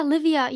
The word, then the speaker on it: Livia: I say your name correctly, don't I, Livia? – Yeah, Livia. Yeah, in Dutch they say Livia, because just random backstory.